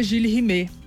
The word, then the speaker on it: Jules Rimet: Em 19 de dezembro de 1983, roubaram a taça Gilles Rimet. Rimet, né? Eu fui ouvir em francês. Gilles Rimet. Mas vou falar Jules Rimet mesmo, pra vocês entenderem.